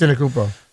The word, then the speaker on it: Antetokounmpo: Says, breaking news, Milwaukee Bucks star Giannis Antetokounmpo. I'm terrible at pronouncing this. – Antetokounmpo. – Yeah, there you go.